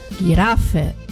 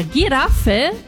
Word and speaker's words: Giraffe: Enquanto em Flieger e Tiger, a pronúncia no Brasil geralmente é boa, na Giraffe há uma derrapada no G, sendo pronunciado quase como G em português.